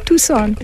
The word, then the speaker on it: Tucson: Ah, tout le monde dit ça, mais le C, c'est sillon. Alors, s'il vous plaît, quand vous venez ici, il faut prononcer Tucson.